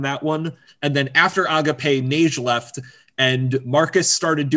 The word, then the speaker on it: Agape: After Neon was, I believe it's pronounced Agape, not Agape, because it's like the Greek concept of Agape. It's Agape. That's the Greek for you.